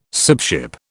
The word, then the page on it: sibship: British English